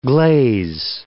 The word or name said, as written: glaze